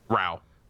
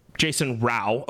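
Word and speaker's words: Rauch: Well, just so that you know, JR and X-Red, current Breaking Benjamin guitarist, I believe it's pronounced Jason Rausch. – Rau. – Jason Rau. Okay, so the CH is silent.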